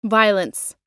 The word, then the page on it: violence: American English